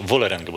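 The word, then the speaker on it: Vålerenga: Vålerengę, bo jak ty tak ładnie to wymawiasz.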